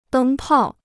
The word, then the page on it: 灯泡: dēng pào